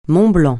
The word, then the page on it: mont-blanc: francais